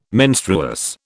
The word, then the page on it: menstruous: British English